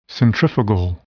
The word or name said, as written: centrifugal